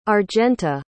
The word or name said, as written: argenta